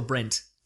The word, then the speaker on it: Brent: Are you saying Brett or Brent? – Brett. – It's Brent. – Is it? – Like a David Brent.